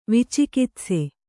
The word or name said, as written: ವಿಚಿಕಿತ್ಸೆ